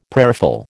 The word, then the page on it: prayerful: American English